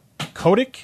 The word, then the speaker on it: Kotick: Basically, the CEO of Activision Blizzard, Bobby Kotick.